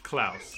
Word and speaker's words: Klaus: When spelled with a K, Klaus is never pronounced like pause. It is pronounced like house. – Klaus.